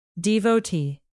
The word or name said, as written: devotee